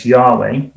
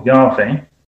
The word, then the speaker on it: Yahweh: So they often transliterated the name as Jehovah, which is actually an inaccurate way of transliterating God's name, because there's never been a J sound in Hebrew. And there's now strong scholarly consensus that the original pronunciation was much closer to what we say today as Yahweh or Yahweh.